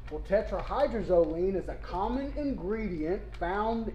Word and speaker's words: tetrahydrozoline: I believe it is pronounced tetrahydrozoline. – Well, excellent job, yes.